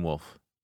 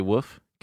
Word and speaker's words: wolf: Hey, how do you pronounce the word W-O-L-F? – Wolf. Wolf. – Wolf? – Yeah. – You why? Don't say woof? Okay, good. – Woof? – Yeah, there's people who call them woofs.